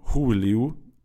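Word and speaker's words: Gouweleeuw: Fangen aber an mit den Rohpunktern und da habe ich die Augsburger Innenverteidiger, Oxford und äh, Huveleu. Huveleu übrigens auch, nicht Huveleu. Es gibt ein YouTube-Video, das wir, uns eingeschickt wurde, von der korrekten Aussprache von Huveleu, also mit H am Anfang.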